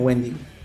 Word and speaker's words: Wendigo: Pues yo lo pronuncio como Wendigo. Pero es W Wendy, o sea, como sí. Wendy, pero Go.